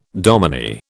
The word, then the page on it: dominie: British English